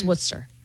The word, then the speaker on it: Worcester: In Boston, it's Worcester, spelled Worcestershire. – Yeah, exactly. – Worcester. – Yeah. – Yeah. It's Worcester. – Yes, it's Worcester. – But it doesn't look like Worcester.